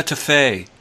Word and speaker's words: Getafe: I was just looking at the 538 rankings, and they have Getafe as the, the number one team to win the Europa League right now, which is pretty crazy to me. – I think it's um, pronounced yeah, Hetafe.